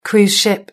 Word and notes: cruise ship: British English

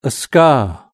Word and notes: a scar: British English

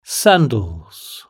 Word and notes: sandals: British English

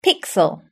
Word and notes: pixel: British English

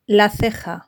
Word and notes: la ceja: Spain Spanish

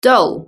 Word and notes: doll: British English

